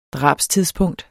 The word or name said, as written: drabstidspunkt